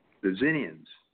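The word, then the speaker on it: Xinnians: In my fourth book now, we're going off to see the Zinnians, spelled with an X, by the way, Zinnian.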